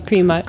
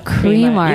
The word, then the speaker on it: Cremart: Am I pronouncing that properly? Cremart? Cremart? – Yeah. Cremart. No, Cremart. – Cremart. You got it. Okay, so it's like cream, but not. Art.